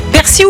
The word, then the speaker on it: persil: Alors normalement c'est persil, ok, ouais. D'après le dictionnaire de l'Académie française. Mais le nord et le sud du pays prononcent quand même le L, donc t'as le droit quand même aux deux.